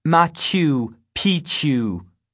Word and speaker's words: Machu Picchu: Machu Picchu. – I thought she was nuts until my mom informed me that this is how it is pronounced.